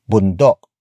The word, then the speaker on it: bundok: Boondocks was borrowed from the Tagalog word bundok.